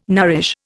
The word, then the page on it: nourish: Indian